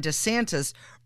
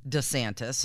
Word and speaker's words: DeSantis: He gets very upset when people, including reporters, don't pronounce it correctly. Now, I have noticed that. I always called him DeSantis. – Mm-hmm. – But now Ron DeSantis has been emphasizing the D.